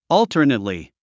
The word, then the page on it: alternately: ɔ́ːltərnətli; オゥタネトリィ